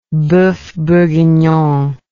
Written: boeuf bourguignon

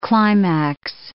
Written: climax

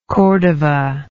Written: cordova

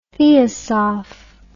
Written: theosoph

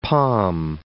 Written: Pom

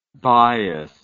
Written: bios

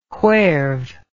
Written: whereve

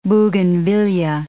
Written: bougainvillea

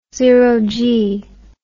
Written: zero g

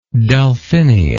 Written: delphinia

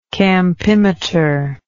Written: campimeter

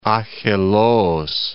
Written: Akheloos

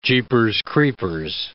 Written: jeepers creepers

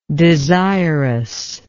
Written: desirousness

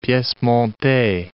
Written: piece montee

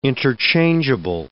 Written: interchangeable

